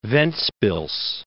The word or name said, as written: Ventspils